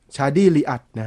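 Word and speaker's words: Chadi Riad: ชาดี้อ่านว่าลีดลีอักใช่ไหมต้องอ่านว่าอะไรวะชาดี้ลีอัดน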